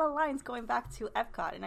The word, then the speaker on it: Epcot: Guardians of the Galaxy really does belong in Epcot from the jokes that they tell. Gamora goes and tells you, like, we're going back to Epco at one point. And then Rocket responds with, it's pronounced Epcot, which is really funny.